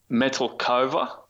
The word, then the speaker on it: Metelkova: Metelkova. – All right, sweet. – So I'm just saying it how it's spelt.